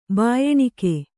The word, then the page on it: ಭಯಾನಕ: bhayānaka